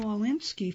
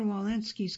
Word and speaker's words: Walensky: Rochelle Walensky, Following the Science, by Julie Gerberding.